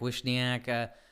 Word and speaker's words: Wishniak: So is it pronounced Wisniak, Wishniak? The people at Frank say it's... – I would say Wishniak. I guess I would have said Wishniak.